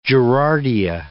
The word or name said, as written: gerardia